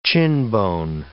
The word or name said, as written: chinbone